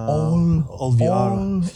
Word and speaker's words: All VR: All VR, kannst du Englisch aussprechen. Das ist immer schöner. – All VR.